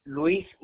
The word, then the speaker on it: Luis: Luis Munoz, L-U-I-S, and then Munoz, M-U-N-O-Z.